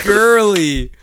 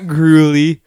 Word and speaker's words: Gurley: It's Todd Gurley, a really good running back. – Gurley, not Gruley or Gruel.